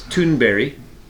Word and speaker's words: Thunberg: Okay, so I happen to know the correct pronunciation of her last name. It's Thunberg, Greta Thunberg, but it looks like Thunberg.